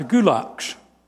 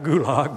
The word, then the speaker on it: gulag: And he's a man who wrote about the gulags. Not gulag, mind you. It's spelled like gulag, but it's pronounced gulag by the Russians.